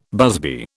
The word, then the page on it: busby: British English